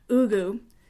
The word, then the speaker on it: Ugwuh: But she met Jason Ugu, which is spelled U-G-W-U-H, but appears to be pronounced, based on videos I found, Ugu Sr., when Tiziana was very young.